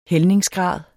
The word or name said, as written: hældningsgrad